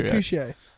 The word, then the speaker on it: Mercutio: I think there is because there is actually a character called Mercurio. – Mercutio.